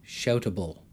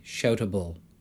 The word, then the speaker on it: Shoutable: Now, if you hear, I pronounced it without an E, Shoutable. Here's with an E, Shoutable. Shoutable. You can probably hear the subtle differences.